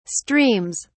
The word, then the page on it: streams: British English